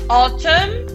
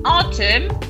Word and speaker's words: autumn: Spring, primavera, spring, summer is coming, summer, autumn, and winter. Cuidado con autumn porque pasa igual que con August, ¿vale? Se escribe A-U-T-U-M-N, ¿vale? Autumn, pero no hay ninguna A ni ninguna U. ¿Vale? Es autumn.